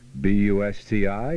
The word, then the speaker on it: Busti: B-U-S-T-I is pronounced Bust-I.